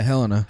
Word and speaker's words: Helena: Is that how you pronounce it? Helena? – Yeah, Helena. – Helena.